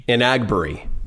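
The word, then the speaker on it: Enagbare: Am I saying that right now? – So it's Inagbari.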